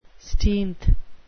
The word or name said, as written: steenth